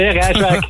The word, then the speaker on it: Rijswijk: Zeg ik dat goed, Rijswijk? Of moet ik het anders... – Rijswijk, ja, een beetje op z'n Haagse, Rijswijk.